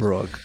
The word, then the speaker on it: Brugge: I mean, Benfica versus Club Brugge. – Brugge. – I don't know how to pronounce that. I apologize. – Brugge. Club Brugge.